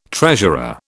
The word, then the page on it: treasurer: British English